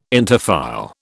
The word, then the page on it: interfile: British English